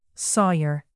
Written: sawyer

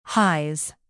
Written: highs

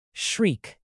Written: shriek

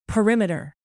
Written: perimeter

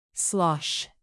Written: slosh

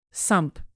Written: sump

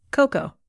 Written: cocoa

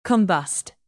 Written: combust

